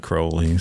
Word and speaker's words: Crowley: There's no audio of him pronouncing his name, but he, in some of his poetry, he rhymed his name with unholy, right? – Unholy Crowley.